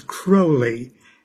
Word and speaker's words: Crowley: Well, the way Aleister Crowley pronounced it was Crowley, and he said it rhymes with holy.